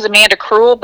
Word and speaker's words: Amanda Kruel: So my name is Amanda Kruel, but it's Kruel spelled with a K.